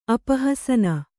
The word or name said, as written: ಅಪಹಸನ